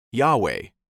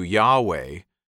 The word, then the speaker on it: Yahweh: It was probably pronounced something like Yahweh.